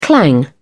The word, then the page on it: clang: British English